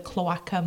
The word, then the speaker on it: cloaca: So I think it's spelt C-L-O-A-C-A.